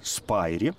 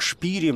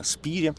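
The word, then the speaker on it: Spyri: И еще раз скажу, что это, в общем, куль, знаете, это можно назвать культовым произведением детской э, литературы европейской, и автор этой книги Иоханна Спири, ее иногда называют, знаете, там, на английский манер Джоанна Спайри. Иногда произносят фамилию как Шпири, но все-таки наиболее наиболее правильная, наверное, Иоханна Спири, писательница XIX века.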